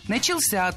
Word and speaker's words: начался: Много раз прозвучал глагол «начался», но это неверно. Правильный вариант – «начался», только так. Ударение в этом слове падает на возвратную часть «ся».